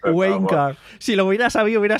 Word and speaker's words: Wanker: Wanker. – O Wanker, ¿no? Bueno, claro, pero lo pronuncian en plan como el acento inglés que es Winecar.